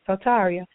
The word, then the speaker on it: Soteria: Her name is Soteria, and in Greek and Hebrew it's pronounced Soteria, and it means salvation, deliverance, prosperity, soundness, and health.